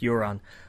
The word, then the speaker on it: Euron: And I know some people pronounce it differently. Get over it. It's pronounced Euron.